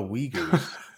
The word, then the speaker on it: Uyghurs: Uyghurs. That's how you say it. – Yes, that is how you pronounce it.